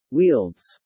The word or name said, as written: wields